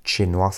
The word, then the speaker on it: Che Noir: Che Noir, ça s'écrit Che, C-H-E, plus loin, noir comme la couleur.